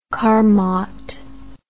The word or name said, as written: carmot